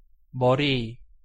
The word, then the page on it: 머리: Korean